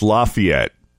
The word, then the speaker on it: Lafayette: No, actually, the accent is on the fee. So Lafayette. Lafayette, Louisiana.